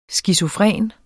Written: skizofren

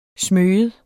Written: smyge

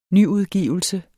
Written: nyudgivelse

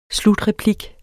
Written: slutreplik

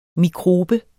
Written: Mikroben

